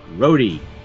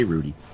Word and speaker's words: Rhodey: He desperately calls out for Rhodey. See? I said it right. I didn't say Rudy.